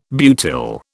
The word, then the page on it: butyl: British English